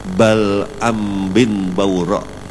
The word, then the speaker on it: Bal'am bin Bawra': Nah, itu bacanya jangan disambung, pokoknya bersambung nah kayak itu. Bal'am bin Bawra'. Bersambung itu. Namanya itu Bal'am bin Bawra'. Karena dibaca langsung Bal'am bin Ambin jadinya Bal'am bin Bawra'.